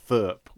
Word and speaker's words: Thrrp: called Thurp, which I think is pronounced. – Yes, because actually the name of the village he was living in Gloucestershire as well, which is spelled T-H-R-R-P or something ridiculous.